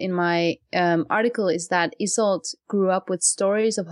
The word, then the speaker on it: Isolde: Yeah, Isolde.